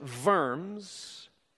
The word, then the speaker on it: Worms: Worms, there was little likelihood that letting him leave would happen.